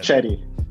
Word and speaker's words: Cheddie: Cheddie.